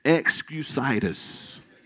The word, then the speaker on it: excusitis: It's excusitis. It's excusitis. Let me spell it for you. E-X-C-U-S-I-T-I-S.